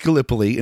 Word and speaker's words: Gallipoli: So anyways, I can tell you it's pronounced Gallipoli in World War One, and written by Travis Webb, pencils by Greg Woronchak, inks by me, and colors by J.W.